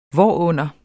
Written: hvorunder

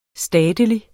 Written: statelig

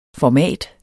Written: format

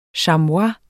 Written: chamois

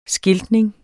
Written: skiltning

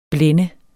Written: blænde

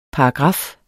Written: paragraf

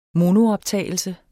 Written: monooptagelse

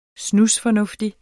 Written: snusfornuftig

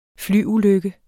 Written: flyulykke

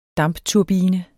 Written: DAMPTURBINE

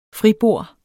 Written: fribord

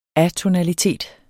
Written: atonalitet

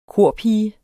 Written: KORPIGER

